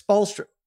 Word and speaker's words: Spoelstra: Are you mispronouncing the Heat coach's name? Spoelstra? You know, it's Spoelstra. I can't tell if you're going out of your way to... – Spoelstra.